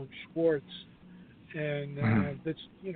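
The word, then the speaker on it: Schwarz: It was designed to be a showcase fight and a mismatch because Tom, his name is pronounced Schwartz, the German pronunciation, which I tried to get, you know, on the conference call for the media.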